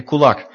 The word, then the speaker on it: kulak: Ja, also wenn ich das auf Russisch ausspreche, Kulak. Und das heißt eigentlich Faust.